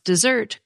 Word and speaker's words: Mount Desert: And indeed, the Mount Desert website says the name is pronounced dessert, quote, since it is of French origin, unquote.